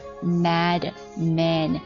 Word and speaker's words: Mad Men: Mad Men，注意一下这个人他的复数读音呢，就是 A 啊 M-A-M，然后 Mad Men， Mad Men，广告狂人们评价一下这部剧啊，大家学一下，我们一起来学一下怎么去评价，有哪些单词，哪些句子，什么样的内容我们可以提到。